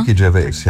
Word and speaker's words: Gervais: Ricky Gervais, ja.